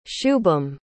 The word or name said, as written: shubham